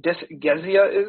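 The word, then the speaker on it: dysgeusia: And does anyone know what dysgezia is? I didn't. I had to look it up. Change in... – Dysgezia. – Dysgezia? Thank you. Is that how you say it? Dysgezia. Yeah.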